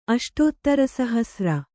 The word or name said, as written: ಅಷ್ಟೋತ್ತರಸಹಸ್ರ